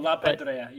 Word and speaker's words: La Pedrea: I'm not sure if I'm saying that correctly. – No, La Pedrea, you got it.